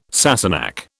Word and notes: Sassenach: British English

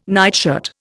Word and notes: nightshirt: Indian

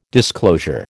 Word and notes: disclosure: American English

